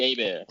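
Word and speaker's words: Hebert: Silent H. – Yeah, because he was like not Bobby Herbert, Bobby A-Bear. He's from Louisiana, man. It's Creole. He's French. He played for the Saints.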